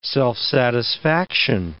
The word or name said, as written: self-satisfaction